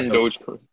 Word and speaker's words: Dogecoin: I'm, I'm up on, uh, I don't know if it's, if I'm pronouncing it correctly, uh, Dogecoin. – Yeah, Dogecoin.